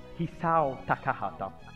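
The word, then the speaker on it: Isao Takahata: Lo studio Ghibli, studio cinematografico ed animazione, da lui fondato nel 1985, insieme al collega e mentore Hisao Takahata. Non so se l'ho detto bene, credo di sì. – Va benissimo.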